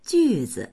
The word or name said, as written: ju4zi